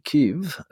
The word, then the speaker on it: Kyiv: We've caught up with you, as I understand it, in Kyiv, as it's now being pronounced, the capital of Ukraine.